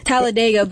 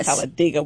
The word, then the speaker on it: Talladega: So let's talk about this history-making thing that you did with Talladega. What's it called? How you say it? – Talladega, but actually... People in Alabama is actually Talladega.